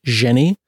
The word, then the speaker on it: Xeni: I think her name is pronounced Jenny. X-E-N-I.